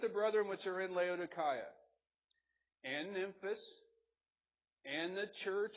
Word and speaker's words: Laodicea: He's like, no, it's Laodicea. But in the Greek, it's pronounced Laodicea.